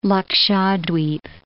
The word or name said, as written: Lakshadweep